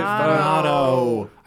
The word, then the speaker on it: Minato: Mineta sort of guy. – What if Minato? – I said Minato.